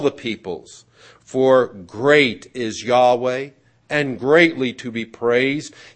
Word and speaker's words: Yahweh: and most likely the correct pronunciation of his personal name is Yahweh, the personal God.